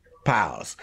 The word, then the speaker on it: Pyles: Please welcome to Money Making Conversation, Navarr, Grevious, and Mikael, Pyles. Pyles, did I say your name right? – You did.